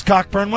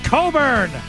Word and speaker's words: Cockburn: Oh, hi, Bruce Cockburn. Why don't you sit over here? – It's pronounced Coburn.